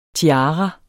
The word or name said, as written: TIARA